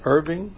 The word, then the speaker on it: Irving: How do you pronounce that? I-R-V-I-N-G. – Irving? – Yeah, Irving.